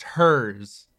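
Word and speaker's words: Herr's: I believe it's pronounced hers.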